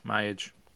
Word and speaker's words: Myage: They open up with, is this pronounced Myage? How do you pronounce this song? – Yeah, Myage. – Myage. Okay.